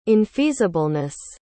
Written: infeasibleness

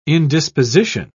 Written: indisposition

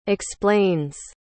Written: explains

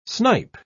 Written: snipe

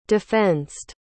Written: defensed

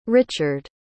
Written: Richard

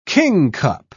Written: kingcup

